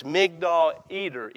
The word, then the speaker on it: Migdal Eder: There was literally, near Bethlehem, on the road between Bethlehem and Jerusalem, a tower known, and I don't speak Hebrew, but it's Migdal Eder, E-D-E-R. That's the southern pronunciation of that.